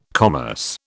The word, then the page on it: commerce: British English